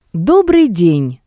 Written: Dobrii den

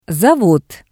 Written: zavod

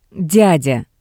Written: dyadya

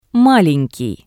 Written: Malenkii

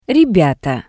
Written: Rebyata

